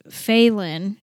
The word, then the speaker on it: Falon: Phelan, pronounced Phelan. Yeah, I know. It's an odd name. Blame my mama. It's spelled F-A-L-O-N.